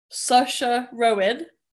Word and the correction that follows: Saoirse Ronan: Maybe Saoirse Ronan. – Saoirse Ronan. Oh, did I say her second name wrong? – You already said sausage for the first bit.